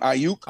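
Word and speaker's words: Aiyuk: What's his name? Ayuk. Did I say it right? – Yeah.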